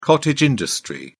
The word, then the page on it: cottage industry: British English